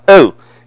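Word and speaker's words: oh (ὅ): Now, in the Greek, the relative pronoun, oh, and I'm not going to spell it because it shouldn't be spelled in here because you'll get the wrong words, but that's how it's pronounced, oh.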